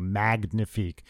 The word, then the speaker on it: Magnifique: And also listen to Mature Situations, the smooth jazz album coming out November 10th, look under Trey Magnifique, and that's with a hard G, Magnifique. It's not pronounced like the French.